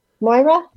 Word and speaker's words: Moira: Am I pronouncing that correctly? – Moira? – Moira. Okay. I forgot the ra.